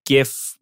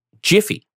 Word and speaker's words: GIF: Well, yeah, but it's also my understanding that the creator of the file format pronounces it Jiffy or GIF.